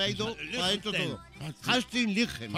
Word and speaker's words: Astrid Lindgren: Que es basada en las novelas de Astrid Lindgren. – Qué bien lo has pronunciado, compañero.